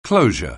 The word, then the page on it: closure: anglais